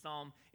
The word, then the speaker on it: Psalm: If you're turning your table of contents trying to find the book of Psalm, it starts with the letter P, all right? So I'm going to throw you off with that silent P.